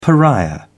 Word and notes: pariah: British English

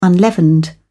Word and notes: unleavened: British English

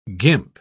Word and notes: guimpe: British English

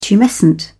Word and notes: tumescent: British English

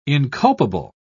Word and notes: inculpable: British English

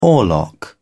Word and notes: oarlock: British English

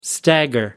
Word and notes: stagger: American English

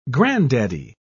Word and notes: granddaddy: British English

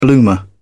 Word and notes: bloomer: British English

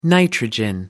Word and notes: nitrogen: American English

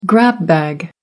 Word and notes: grab bag: American English

